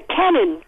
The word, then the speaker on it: Kenan: My contribution to the opposite of Karen is a Kenan, K-E-N-A-N. It's a male name, starts with a K, rhymes with Karen, and it's kind of like a swishy, you know...